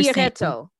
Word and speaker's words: Chiaretto: So, but you're Chiaretto. – Saying. – Chiaretto. – Chiaretto. – Yes. – Okay. – C-H-I-A-R-E-T-T-O. – Chiaretto.